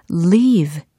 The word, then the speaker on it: leave: leave, L-E-A-V-E e 이라는 철자입니다. 남겨두다, 떠나다 라는 의미로도 쓰이잖아요. leave 해보세요. leave, leave, leave. 어, 뭔가 좀 의도가 담뿍 들어져 있습니다. 좀 길게 끄는 경향이 있어요. leave. 그래요. leave가 아니라 leave. 길게 발음을 해야 됩니다.